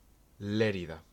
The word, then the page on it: Lleida: Spanish